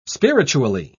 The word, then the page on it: spiritually: British English